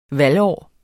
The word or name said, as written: valgår